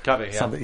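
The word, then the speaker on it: Wendy McLendon-Covey: Am I saying that right? Wendy McCovey. – McCovey, yeah.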